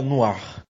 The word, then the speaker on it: Noir: Essa pronúncia é Noir.